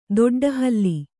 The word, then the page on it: ದೊಡ್ಡಹಲ್ಲಿ: doḍḍa halli